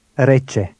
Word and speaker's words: Rece: Rece. Rece. – Did you pronounce the rolling R as the speaker did?